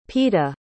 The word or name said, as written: peta